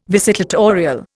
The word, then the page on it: visitatorial: Indian